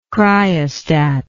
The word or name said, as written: cryostat